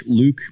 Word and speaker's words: Louque: You can find me on Twitter at Jake Louque.